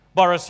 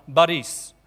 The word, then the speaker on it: Boris: We get the emphasis on the wrong syllable and they don't sound right. So, like President Putin, what's his first name? Vladimir. No, Vladimir. Boris Yeltsin. No, Boris.